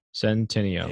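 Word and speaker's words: Centineo: I put in his last name and I just hit pronunciation. Santino.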